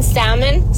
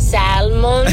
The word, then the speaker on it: salmon: Shaboy, you're hella Mexican when you pronounce the L in salmon, salmon.